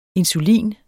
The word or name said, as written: INSULINEN